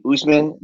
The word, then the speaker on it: Usman: Usman.